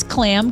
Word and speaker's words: Klamm: Name is Clam. – Mm. – K, it's spelled K-L-A-M-M.